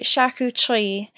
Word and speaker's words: charcuterie: It's a very prevalent part of French cuisine, and they pronounce it charcuterie, very French, you know.